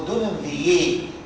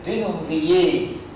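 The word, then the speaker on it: Denonvilliers: فشه اوف دينوم فييه راجل فرنساوي بيتكتب كده دينون فيلرز، ده نطقه كده بص بيتكتب دونون فيلرز بس الفرنساوي اخر حرفين بيشيلوهم اسمه دونون فييه. راجل فرنساوي اسمه ايه؟ دونون فييه، فرنسوا ميتيران كده يعني، فاهمين قصدي؟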